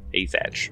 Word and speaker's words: Athach: A T H A C H, Asach.